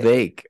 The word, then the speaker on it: Vivek: It's Vivek, actually. – Vivek. Okay. – Yes. – Yeah. – My friends say you can bake a Vivek cake. – Vivek. – So, yeah. Rhymes with bacon cake.